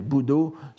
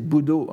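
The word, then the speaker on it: budo (ぶどう): budo, ce, ne sont pas deux caractères, bou et do, c'est budo en, un seul mot.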